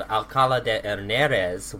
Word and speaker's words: Alcalá de Henares: So Catherine was born in a city near Madrid called Alcalá de Henares, which that was really good pronunciation.